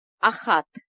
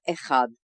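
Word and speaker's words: Ахат (אחת): Очень часто израильтяне, которые произносят миспар стами абсолютно правильно, делают ошибку в одном единственном числительном. Это числительное ахат. Вот все числительные в номерах телефонов они произносят в женском роде, а вместо ахат почему-то ошибочно говорят эхад. Не делайте эту ошибку. Но когда услышите ее, знайте, это ошибка.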